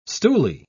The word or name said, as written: stoolie